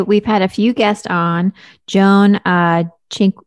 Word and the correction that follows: Cinquegrani: I'm going to say her name wrong. – Yeah, Joan Cinquegrani.